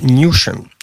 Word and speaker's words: Newsham: Mianowicie niedawno została opublikowana książka emerytowanego pułkownika Grant Newsham. Pisze się trochę łatwiej niż się wymawia. Są to dwa słowa news i ham razem.